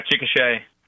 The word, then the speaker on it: Chickasha: I'm going to go down to Chickasha, Oklahoma. Did I say it right? Chickasha? – Yeah, Chickasha.